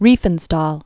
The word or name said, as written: Riefenstahl